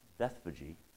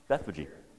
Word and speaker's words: Bethphage: This Colt in Bethany, or Bethphage. That's how you pronounce it. I YouTubed it, yeah. Uh-huh. Bethphage.